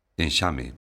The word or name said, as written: enxame